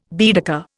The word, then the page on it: Baedeker: Indian